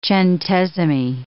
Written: centesimi

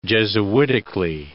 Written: jesuitically

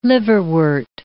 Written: liverwort

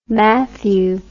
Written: Mathew

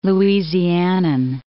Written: Louisianan

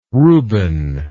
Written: Ruben

